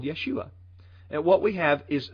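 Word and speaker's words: Yeshua (ישוע): pronounced Yeshua.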